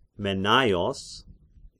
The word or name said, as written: Mennaios